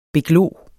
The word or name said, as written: beglo